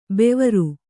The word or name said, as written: ಭವಾರಿ